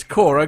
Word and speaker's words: Corps: It says it's got Corps. Corpse written down here. – You were given a pronunciation guide. – There's in red. – Oh, there's a pronunciation guide that I didn't bother with. So it's Core.